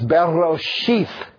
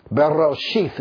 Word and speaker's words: Bereshith: The first word in the Old Testament is Bereshith. Bereshith. If you hear the middle syllable, Rosh, Berosh, Bereshith. Say that with me. Bereshith. Very good. The middle word is Rosh. R-O-S-H.